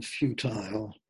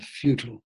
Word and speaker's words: futile: Oh, English pronunciation, futile. American pronunciation, futile.